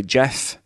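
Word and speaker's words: GIF: No, I think the creators of the format have come out and said that it is actually Jeff. – Yeah. – But I refuse to believe something that stands for graphics interchange format. – Mm-hmm. – It's supposed to be pronounced with a J sound.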